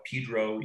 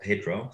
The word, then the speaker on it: Pedro: Yet they call it Pedro, even though it's spelled Pedro.